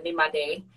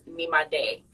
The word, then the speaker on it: Nyemadye: so my name is Nyemadye Sasha Hughes, or as my family says in Liberia, Nyemadye. And so I go by Sasha, but my brand and my name is Nyemadye. That's how you pronounce it.